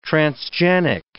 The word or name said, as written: transgenic